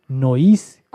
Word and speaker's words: noise: Nois, como oís o como nois. Nois es una palabra que se utiliza mucho en el mundo de la radio experimental para decir ruido. Nois es ruido. Se escribe como noise.